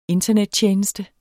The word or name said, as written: internettjeneste